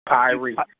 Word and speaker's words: Upyri: Upyri? – Upyri. – Upyri.